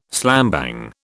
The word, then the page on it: slambang: British English